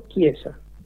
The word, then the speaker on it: Chiesa: O, de acuerdo a la pronunciación correcta en italiano, Chiesa.